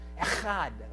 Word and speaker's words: echad: That word one there is the Hebrew word, and it's pronounced something like this, echad. Right? You got to kind of clear your throat when you're saying that. So everybody try that with me.